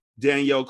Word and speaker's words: Dhonielle: To which another YA author, Danielle Clayton, and she's white, and her Danielle is spelled D-H-O-N-I-E-L-L-E, Danielle. She added, can I add a few more choice words for Siobhan's brilliance?